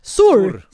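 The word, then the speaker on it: Sur: Då kommer Maria Saur. Så är det Sor.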